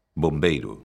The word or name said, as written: bombeiro